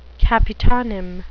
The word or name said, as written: capitonym